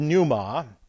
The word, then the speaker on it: pneuma: So the first thing they saw was you have the Greek word pneuma, and they realized, and by the way, this is the problem that you get into. Here we have it, P-N-E-U-M-A, pneuma, the P is silent, P-N-E-U-M-A. My Greek is good, my English is terrible. P-N-E-U-M-A, pneuma, that is the word.